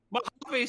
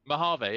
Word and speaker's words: Mojave: Mojave, see, there you go. – Mojave, yeah. – It is. – That's how you pronounce it, yeah.